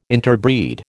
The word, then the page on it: interbreed: American English